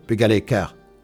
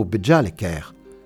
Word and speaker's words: Bujalekar: Bonjour, Bugalekar. » C'était plutôt Bujalekar, le terme désignant les enfants, ayant sa prononciation locale.